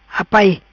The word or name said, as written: Apai